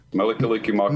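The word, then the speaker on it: Mele Kalikimaka: Mili Kalikimaka Mai Ohana. It means Merry Christmas to the family. – All right, here's a clip of that song. This is Mela Kalikimaka Mai Ohana. – Mili Kalikimaka Mai Ohana.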